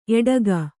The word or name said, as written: ಎಡೆಗೋ